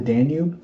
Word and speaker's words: Danube: Several people were rumored to have thrown themselves into the Danube. That's the cat. – Yeah, I can see her. The Danube? – Danube? Is that how it's said? – Mm-hmm.